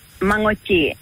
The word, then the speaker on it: Manoodji: It's called Manoodji, the tail end, the tail end sound is nga, nga, nga.